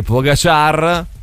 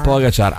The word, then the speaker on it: Pogačar: Tadej Pogačar, non si dice così. – Pogačar ha vinto il Tour de France.